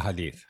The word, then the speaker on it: Hadith: That's the Hadith, yes. – A Hadith.